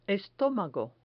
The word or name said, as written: estomago